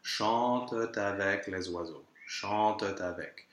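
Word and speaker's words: chantent: Now, here, chante is a verb in the third person of the plural, so that ENT ending is actually just a schwa with a silent T, which means that we can have the liaison after.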